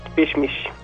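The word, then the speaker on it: Pişmiş: Pismis. – Pismis, con SH más o menos, ¿ok? – Sí, básicamente.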